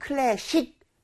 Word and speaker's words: classic: 근데 여기 중간에 S가 C 사운드잖아요. C. – Yeah. – 그렇기 때문에 무슨 SH인 것처럼 classic. 쉿 이런 식으로 발음하시면 안 돼요.